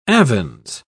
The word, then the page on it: avens: British English